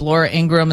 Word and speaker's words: Ingraham: Laura Ingraham, is that how you say her name? – Yeah.